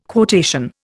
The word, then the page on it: quotation: Indian